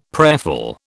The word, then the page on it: prayerful: British English